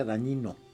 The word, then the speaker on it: Dagnino: Dagnino en italiano se pronuncia dañino. La GN, ya, sí, sí. – Ellos no tienen ñ, entonces la es GN suena como ñ nuestra.